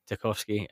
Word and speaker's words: Tarkowski: And I think I've seen some stuff about is it, how do you pronounce his name, Tawarski, Taninski at Burnley, the centre half? – Tarkowski. Yeah.